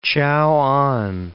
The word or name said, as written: Chao'an